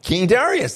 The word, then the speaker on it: Darius: King Darius. – King Darius. That's exactly right.